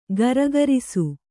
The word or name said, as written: ಘರ್ಘರಿಸು